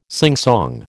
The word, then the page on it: singsong: American English